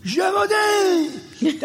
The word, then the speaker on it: Gévaudan: And he puts his glass on and he goes, Gévaudan! That's the Gévaudan! That's what it is, Gévaudan! Right? That's how I got the pronunciation of that name.